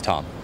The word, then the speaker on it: Tom: My name's Tom. – How do you spell it? – T-O-M.